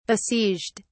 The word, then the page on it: besieged: British English